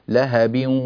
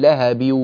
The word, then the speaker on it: لهب: ملاحظة تجلت في موضعين، الإدغام بغنة يا ياسين يستلزم أن نأتي بالإدغام وأن يصحبه من الأنف غنة مع التلاوة، تقول لهب وتب، لهب وامرأته، لو سددنا الأنف يظهر لك لهب، لاحظ الأنف يعمل، لهب، لكن لا تقول لهب الأنف لا يعمل، أنت تلفظ بواو طويلة بس لكنك لم تأتي بغنة.